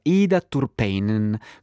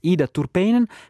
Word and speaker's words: Ida Turpeinen: Allora, Ida Turpeinen, come si scrive? Si dice Ida Turpeinen, classe 1987, che nella sua traduzione italiana dell'ultimo romanzo ha ricevuto una nomination al Premio Strega Europeo 2025.